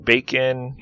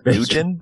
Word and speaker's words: Bakin: Bacon, B-A-K-I-N. Bacon. That's how I pronounce it.